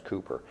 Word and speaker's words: Cowper: It looks like Cowper, but it's pronounced Cooper.